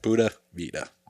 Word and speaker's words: Pura Vida: Pura Vida. – Pura Vida, which is pure life, and they use it for everything. And so that's what they told Hunter, like, about his bag, Pura Vida.